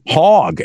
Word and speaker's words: Hawg: And also, we have to play the song called Blind Hog, and it's spelled H-A-W-G. So it's like hog, right? – There you go.